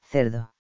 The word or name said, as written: cerdo